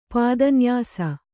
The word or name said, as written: ಪದನ್ಯಾಸ